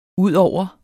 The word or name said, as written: udover